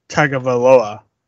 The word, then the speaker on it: Tagovailoa: It pains me to say that he is starting Tua Tagovailoa. – Oh, you got it right. Oh, my God. – I know how to pronounce Tagovailoa.